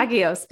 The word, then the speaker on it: Hagios: And you recently launched, I hope I pronounced it right, the Hagios, Hagios. – Study. Yeah, Hagios, good.